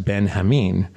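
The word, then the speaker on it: Benjamín: So a character, the translator, whose name is spelled G-E-N, is pronounced Gen, not Jen. And there's what I would have called General Benjamin is pronounced Ben-Hamin, which that makes sense, I guess, because it's a Spanish setting.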